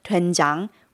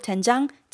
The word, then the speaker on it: doenjang: Especially if you're new to Korean cooking, Eric recommends starting with doenjang, which is often translated to soybean paste. – It's spelled D-O-E-N-J-A-N-G.